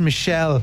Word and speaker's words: Michel: No, he's French. It's Michel. It's spelled different.